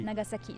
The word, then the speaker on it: Nagasaki: Nagasaki, les S se prononcent ici.